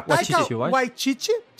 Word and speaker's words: Taika Waititi: Isso é simplesmente a mais nova comédia romântica histórica de aventura aí dirigida pelo por muitos diretores, mas pelo Taika Wai, como é que fala? – pelo Taika Waititi, dirigido pelo Taika Waititi, escrito pelo David Jenkins.